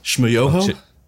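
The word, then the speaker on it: Schmoyoho: Shmoyoho? Accent on the Yo? – Oh, that's you, the Gregory Brothers.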